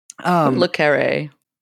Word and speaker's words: Le Carré: Le Carré.